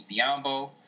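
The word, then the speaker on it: Biyombo: He resigns in Toronto, which probably means Biombo, which rhymes with Mutombo, is out of there.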